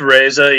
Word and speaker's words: Reza: It's Reza, yeah. – Reza. – It's spelled with the E, but you say it with an A. It's one of those weird Persian last names.